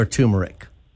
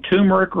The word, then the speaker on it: turmeric: So there's a plant called turmeric or turmeric. We can pronounce it either way.